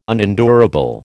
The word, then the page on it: unendurable: American English